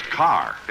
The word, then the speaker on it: Kerr: Jack, her name is pronounced Carr, isn't it, Deborah? – Yes, but that's all right. A lot of people make that mistake. – What do you mean mistake? It's spelled K E R R, isn't it? – Yes, but in England it's pronounced car.